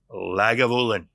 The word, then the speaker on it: Lagavulin: So, Lagavulin. Yes. Is that how you say it? It is. Awesome. Dude, I nailed that one.